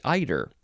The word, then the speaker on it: eider: It looks like cider. Like I, I had some cider last night. Uh, but uh, this one, instead of a C at the beginning, it's an E, but it is pronounced this essentially the same way eider, cider, eider.